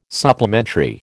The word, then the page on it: supplementary: American English